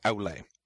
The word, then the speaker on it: Aule: Aule, the AU sound, sounds like ow. So Aule.